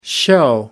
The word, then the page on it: show: American English